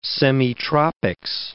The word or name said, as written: semitropics